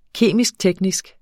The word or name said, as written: kemisk-teknisk